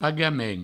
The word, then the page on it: pagament: pagamen